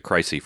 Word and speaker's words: Chryse: Yeah, it was the spelled C-H-R-Y-S-E, but they pronounce it Crisy for some reason.